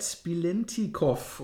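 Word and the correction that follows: Biletnikoff: oder? Biletnikov. Biletnikov, genau. Ja.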